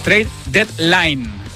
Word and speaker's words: trade deadline: Pues ayer se cerró el mercado, sí, el trade deadline. Ahora lo he dicho bien, ¿verdad, Víctor? Ahora sí, trade deadline, vamos, límite 24 horas.